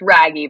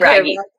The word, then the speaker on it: Wragge: It's Raggy, but that's fine. – Okay. – Raggy. Yeah, it's okay. It's German. It's technically Raggy. So it's hard to pronounce.